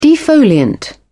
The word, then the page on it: defoliant: British English